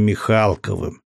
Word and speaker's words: Михалков: Хотя, судя по гербам на авто, предстояло ему не менее интересное свидание с князем Михалковым Никитой Владимировичем. Ударение на букву «А».